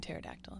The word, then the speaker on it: pterodactyl: It's a silent S. It's like the P in pterodactyl. It's like silent.